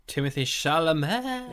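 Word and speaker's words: Timothée Chalamet: I've not seen much with, how do you say his name on the podcast? – Oh, Timothy Chalamet.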